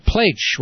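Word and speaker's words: Pleich: And that's Steve Plaich, rhymes with H, P-L-E-I-C-H, Steve Plaich.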